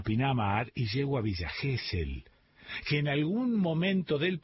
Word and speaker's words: Gesell: Gesell es un apellido de origen alemán. Quiere decir compañero y se pronuncia Gesell.